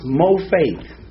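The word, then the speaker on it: mopheth: Is pronounced Mofaith. And that's not Ebonics. Okay? But it's actually the word Mofaith. It's M-O-P-H-E-T-H. And they pronounce it in the Strong's Concordance as the word faith.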